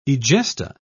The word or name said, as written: egesta